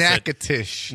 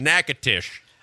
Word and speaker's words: Natchitoches: And I live in Notish or whatever they said how to pronounce Natchitoches. – It. Natchitoches.